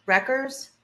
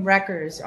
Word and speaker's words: Reckers: Mitch Reckers. – Do we spell that, please? – M-I-C-H, last name Reckers, R-E-C-K-E-R-S.